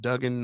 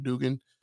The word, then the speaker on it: Duggan: I think it's pronounced Duggan, not Dugan.